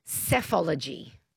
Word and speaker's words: psephology: PSEphology. – Yeah, okay, good. Good. That's the one. It's yeah, and it's pronounced cephology.